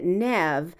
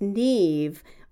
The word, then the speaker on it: Neve: Nev Campbell pronounces it Nev, but I've also heard it pronounced Neve, which is the Irish pronunciation, and both are used in the United States.